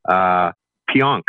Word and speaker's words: Pionk: To the Rangers from Winnipeg for Neil Pionk, I think it is. – That is correct.